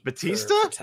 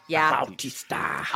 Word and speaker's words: Bautista: Batista? Wow, that is. – Batista. – Bautista. – I know that's how you actually pronounce his name. I'm sorry, I watched wrestling for too long.